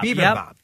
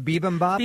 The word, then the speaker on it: bibimbap: Bibimbap. – And that's the traditional Korean rice bowl. – Is that the right way to say it? Bibimbap? – Bibimbap. Yep.